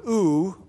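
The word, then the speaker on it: ou (οὐ): The speaker or writer can use the little word ou, or he can use the word may, M with a long A, ou or may. Both of those words mean no or not, and both of them are used throughout the Greek New Testament.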